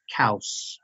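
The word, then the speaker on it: Coues: Yeah, the tags are a little tough, but those coos deer are pretty much over the counter, aren't they? – Yeah, I know somebody's going to comment on this, probably say it's pronounced cows, which is true.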